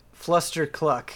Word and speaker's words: Fluster Cluck: I'm proud of you that you said it correctly. Fluster Cluck. – Yes. – I definitely would have mixed it around for sure. Good job. – It's like Fuddruckers.